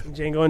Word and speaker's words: Django: Django Unchained, because the D is silent.